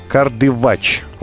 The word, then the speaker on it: Кардывач: Кроме э, реки Мзымта могу отметить э, горное озеро Кардывач, вот на А ударение, э, или там же изумрудный водопад.